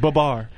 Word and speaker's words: Babar: But it's Sorry, more, Fetty it's more like the elephant, so it's pronounced Babar. – Babar.